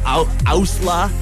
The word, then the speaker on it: OWSLA: And now on Skrillex's label, Ousla. I can never pronounce it, O-W-S-L-A, comes a fresh release.